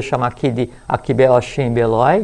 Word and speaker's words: Beloi: A-S-H-I-M. – M. M. – Axim. A-S-H-I-M. É. Anota aí para pesquisar. Beloi, B-E-L-O-I. Beloi. – Beloi. Isso.